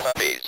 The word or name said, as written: puppies